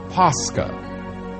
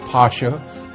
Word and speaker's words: Pascha: Pascha. Sometimes pronounced Pasha, but Pascha is the word.